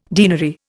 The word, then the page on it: deanery: Indian